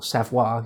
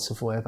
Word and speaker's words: Savoie: There's all sorts of things like Savoy, Piedmont, or Savoie, you should probably pronounce it rather than Savoy, but I'll be saying Savoy.